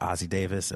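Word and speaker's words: Ossie Davis: Like, is it Ozzie Davis?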